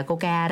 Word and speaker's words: KOCCA: Đó, cái đó đọc là KOCCA là Coca đó, hay phải nói là Coca vậy đó.